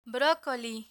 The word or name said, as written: brocoli